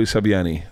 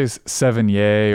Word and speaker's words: Sevigny: Uh, you can say Sevigny or that, I like Sevigny. – Yeah, that's the French.